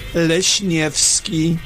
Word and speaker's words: Lesniewski: Leshnevsky.